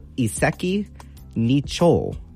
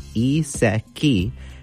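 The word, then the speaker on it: Iseki Nicho (一石二鳥): So again, it's going to be Iseki Nicho. So it's going to be pronounced in two different words here. So the first one is Iseki, Iseki, and the second part is Nicho, Nicho.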